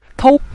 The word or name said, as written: tou5